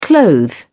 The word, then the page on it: clothe: British English